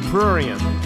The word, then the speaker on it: prurient: However, yeah, we can assure you that all subsequent motivation was strictly prurient. – Prurient. – Yeah, that's a classy word. – Yes, prurient rhymes with orient and means lustful.